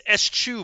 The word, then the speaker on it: eschew: It's eschew, by the way. – Eschew. All right, yeah. – Eschew, which I would have never – I didn't know that's how you pronounce it.